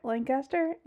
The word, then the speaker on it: Lancaster: I know you practice when you moved here how to say it the correct way. Lancaster, Lancaster, Lancaster.